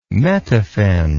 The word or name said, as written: metaphen